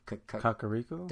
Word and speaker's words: Kakariko: Kakariko? – Thank you.